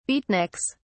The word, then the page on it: beatniks: British English